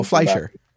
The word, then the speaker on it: Fleischer: Flexinger, or I can never pronounce his name, the cartoon. – Oh, Fleischer.